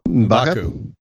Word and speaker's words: M'Baku: M'Baku.